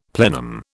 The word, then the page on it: plenum: British English